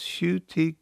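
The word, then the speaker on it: Ncuti: His name is pronounced Shuti Gatwa.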